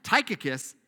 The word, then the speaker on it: Tychicus: So the main rule when you're reading the Bible is you pick a pronunciation, you say it with confidence, and you say it the same way. So Tychicus is what it's going to be.